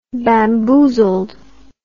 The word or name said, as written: bamboozled